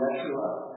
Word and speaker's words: Yeshua: You hear sometimes people call him Yeshua, Aramaic pronunciation.